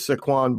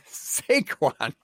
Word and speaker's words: Saquon: He did extremely well filling in last year with Saquon Barkley out. – Saquon, man. – Saquon. – You have the weirdest thing with pronunciations. Like, you're putting the emphasis on the wrong syllable.